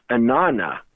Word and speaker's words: Inanna: And in Aramaic, it sounds like this, Inanna, which is actually one word, Anna, said twice, Anna, Anna. And when I look on the page, I see Anna, Anna. But the way we pronounce that, the middle ah sound drops and it becomes Inanna, which intriguingly is the ancient Sumerian goddess of love, fertility, and warfare.